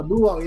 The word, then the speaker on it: Blue Lock: Is it Blue Lock? Am I saying that right? Yeah, Blue Lock.